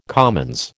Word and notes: commons: American English

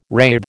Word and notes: rathe: American English

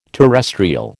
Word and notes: terrestrial: American English

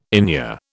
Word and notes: inure: British English

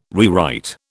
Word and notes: rewrite: British English

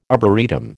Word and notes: arboretum: American English

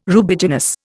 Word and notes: Rubiginous: Indian

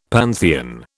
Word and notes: pantheon: British English

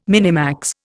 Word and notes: minimax: Indian

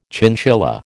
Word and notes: chinchilla: American English

